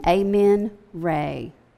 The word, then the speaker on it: Amen Ra: For example, Ihu, or who he, was the title of the one supreme deity, Ammon Ra, and that's actually, I think I've been saying that wrong, Ammon Ra. You can look at that, A-M-E-N, Amen Ra.